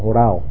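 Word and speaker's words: jorao (ὁράω): Esta es la palabra, se escribe H-O-R-A-O. A veces en el español lo ponen como jorao porque la H, este acento de aquí es el acento fuerte. El acento que va así, el acento fuerte, se pronuncia como una J, pero es una H. En el español, claro que no pronunciamos la H al comienzo, pero ese es orao pronunciado como jorao.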